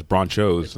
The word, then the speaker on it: Bronchos: Yeah, it's Bronchos. That's Bronchos. – How it's spelled. – There's no H. – Yeah. Don't ask me why. – Bronchos. – Yeah, there we go. – Exactly. – It's like Bronc Nachos.